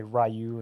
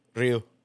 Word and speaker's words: Ryu: because obviously I already know how to play Ryu and fucking. – It's pronounced Ryu.